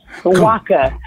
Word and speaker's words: Nwaka: Nwaka. – Thank you. – Silent N. Yes.